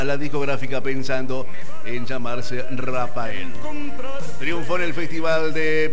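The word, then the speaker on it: Raphael: Se lee Rafael, por eso yo cuando recién presentaba decía Rafael o Rafael.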